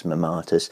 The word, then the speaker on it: mammatus: Tell us about the mammatus. – Well, you pronounced it wrong.